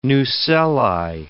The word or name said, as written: nucelli